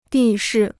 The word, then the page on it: 地势: dì shì